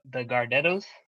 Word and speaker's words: Gardettos: And then next, you guys might go crazy, but I actually really like these, the Gardettos, if that's how you say it.